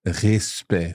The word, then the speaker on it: respect: And at the end of the word, so E-C-T. So yep. C-T, two consonants. So E, respect. – Respect, with a silent C in there.